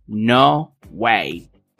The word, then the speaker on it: no way: Repite conmigo, no way. Esta frase se pronuncia como si fuera una sola palabra, no way, y tiene un significado parecido a no puede ser que no está muy lejos de qué fuerte.